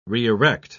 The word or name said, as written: re-erect